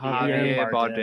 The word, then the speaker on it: Javier Bardem: I thought Harvey R. He's Harvey R. How do you say it? Javier, Javier Bardem.